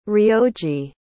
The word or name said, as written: ryoji